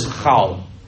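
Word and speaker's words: chal (חל): And this Hebrew word is chal, chal. It's spelled chet lamed.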